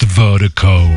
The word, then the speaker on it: Virdiko: And Vertigo is spelled V-I-R-D-I-K-O. That's V-I-R-D-I-K-O. Pronounced Vertigo.